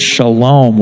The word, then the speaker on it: shalom: That's what this word shalom, where we get peace from. Shalom is how you say it in Hebrew.